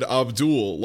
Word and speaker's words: Abdul: And she's like, well, when I asked him how to pronounce his name, he said Abdul, like Paula Abdul.